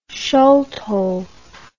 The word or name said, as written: sciolto